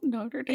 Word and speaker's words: Notre Dame: Notre Dame.